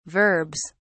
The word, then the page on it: verbs: British English